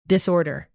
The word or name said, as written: disorder